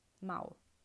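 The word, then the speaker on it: Mao (猫): Mao. Eh? Ma è facilissimo, sembra un miagolio, Mao.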